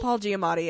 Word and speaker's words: Paul Giamatti: And his friendship with the knockoff Paul Giamatti? – Paul Giamatti, yeah.